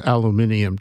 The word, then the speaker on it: aluminium: aluminium tubes. Aluminium is the English pronunciation.